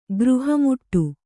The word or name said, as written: ಗೃಹಮುಟ್ಟು